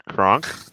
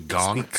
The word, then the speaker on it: Gonk: It's pronounced Gronk, and he was voiced by Patrick Warburton. Show some respect. – Uh-huh. No, it's actually Gonk.